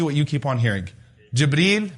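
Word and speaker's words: Jibreel (جبريل): What are some of the ways to pronounce his name? Jibreel, Jibra'il, Jibra'il, Jibra'il, Jibreel. Those are five ways that have been narrated through the various qira'at.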